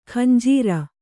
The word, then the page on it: ಖಂಜೀರ: khanjīra